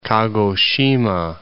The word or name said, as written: Kagoshima